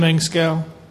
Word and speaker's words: Engskow: Jim Engskow. I'm with Laytide Roofing. – So if you, could you spell your last name? – Engskow is spelled E-N-G-S-K-O-W. – Okay, it's Jim E-N-G-S-K-O-W, Engskow.